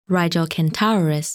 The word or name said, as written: Rigil Kentaurus